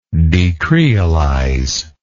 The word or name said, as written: decreolize